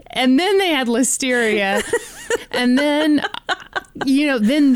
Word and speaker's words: Listeria: They'd already dealt with the Listeria crisis in 2015. – I'm sorry, the what crisis? – Am I saying that wrong? It's yeah. Listeria, isn't Listeria. – It? Listeria. Listeria.